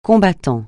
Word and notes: combattant: francais

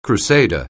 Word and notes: crusader: anglais

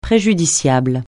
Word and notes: préjudiciable: francais

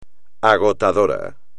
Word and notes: agotador: espagnol

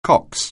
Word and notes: cox: anglais

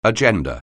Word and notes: agenda: anglais

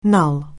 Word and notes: null: anglais

